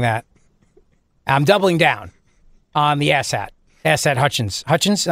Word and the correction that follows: Hutchinson: No, you mispronounced it. Hutchinson.